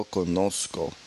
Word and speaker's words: conozco: Yo conozco.